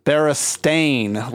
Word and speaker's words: Berenstain: The Berenstain Bears. – What is Berenstain Bears? It's wrong. – Berenstain Bears. – Neither is that. – What is it? – Berenstain, like a paint stain.